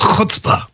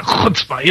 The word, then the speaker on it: chutzpah: It's called chutzpah. It's got great phlegm when you pronounce it, chutzpah, you know.